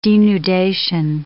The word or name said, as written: denudation